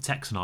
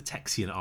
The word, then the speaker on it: Texian: However, later in that war, he'd been captured by the Texian army, and it's not the Texan army, it's the Texian army.